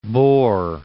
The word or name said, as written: Bohr